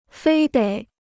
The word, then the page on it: 非得: fēi děi